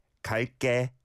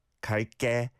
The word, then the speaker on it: 갈게: It is pronounced with a tense 게, 갈게, 갈게.